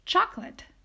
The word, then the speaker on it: chocolate: So the first word that is often mispronounced is chocolate. Chocolate. So even though it has like, I don't know, more than four syllables, it is actually only pronounced with two syllables, and that is chocolate.